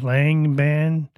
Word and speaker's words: Langebaan: It's a place, it's LANGEBAAN, so Langban.